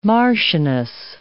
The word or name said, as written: marchioness